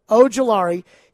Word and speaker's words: Ojulari: And a guy a lot of people have mocked to the Titans, Azeez Ojulari. He's got – I keep mispronouncing that. – Ojulari.